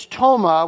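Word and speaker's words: toma (πτῶμα): This is toma with a silent P on the beginning of it, and it refers to a lifeless body, what we would call a corpse.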